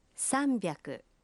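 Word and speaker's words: Самбяку (さんびゃく): Правильно, но с произношением может возникнуть путаница. 300 произносится… «Самбяку».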